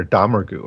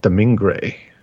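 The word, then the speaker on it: Domergue: And she corrects the pronunciation, right, for the first time. So it's like Domingue, which I'm not sure that's the proper French pronunciation either, but...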